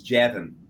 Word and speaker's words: Javin: It's Javin. – Javin. I guess just like it looks. Okay. – Just like it looks, yep.